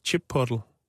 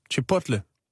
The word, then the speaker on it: Chipotle: det udtales Chipotle. – Chipotle? – Chipotle.